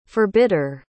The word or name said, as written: forbidder